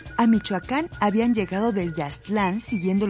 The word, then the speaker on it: Michoacán: Bueno, pues entonces, para finalizar, recordaremos que Michoacán, cuya verdadera pronunciación es Michoacán, significa lugar que tiene pescados.